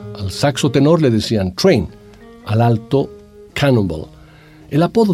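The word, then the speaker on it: Trane: Sin embargo, Train, T-R-A-N-E, a pesar de que se escribe así, tiene el mismo sonido que la palabra que viene de tren en inglés train, T-R-A-I-N.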